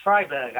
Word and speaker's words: Freiburg: Freiburg, actually. It's... E-I in German is pronounced I and I-E is pronounced E.